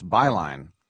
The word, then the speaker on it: Beilein: Now, if he pronounced his name properly, it's Beilein, as in this network.